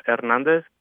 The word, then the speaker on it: Hernandez: Are you pronouncing that with an H or not? – Hernandez. You really don't sound the H.